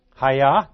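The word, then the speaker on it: Haya (היה): Haya.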